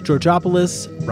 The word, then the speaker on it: Georgopoulos: Our theme song was created by Alexis Georgopoulos, rhymes with Metropolis.